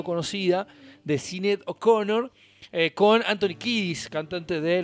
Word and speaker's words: Sinéad O'Connor: Y aparece una figura realmente importante de la música como Sinéad O'Connor, con acento en la E, Sinéad O'Connor, artista irlandesa que lamentablemente, tristemente hoy falleció a los 56 años.